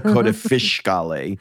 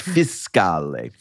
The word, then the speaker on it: fiscale: And he's like, it's not a fish. It is not a coda fiscale. It's fiscale.